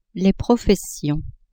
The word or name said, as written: professions